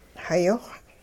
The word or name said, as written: hayi-xw